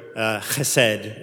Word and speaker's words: chesed: The word used for love here, chesed, is my best pronunciation of the Hebrew.